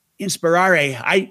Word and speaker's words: inspirare: You know, inspire comes from the Latin term inspirare. I don't think I pronounced that right. – Oh, you did.